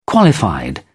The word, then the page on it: qualified: British English